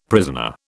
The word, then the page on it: prisoner: British English